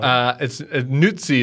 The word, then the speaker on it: Nuzzi: Nuzzi is the correct way to pronounce it.